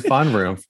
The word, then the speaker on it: VonRumpf: Did I say that right? – Oh, wow. Yeah, you just kind of rolled it quick. VonRumpf. – I just rolled it, and then I was like, wait, I didn't say that right. After he told me. – We got LeVon VonRumpf here. It's Lo. The last name is VonRumpf.